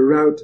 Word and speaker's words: route: I'm sorry, I pronounced that. The route that they followed. I say it the English way.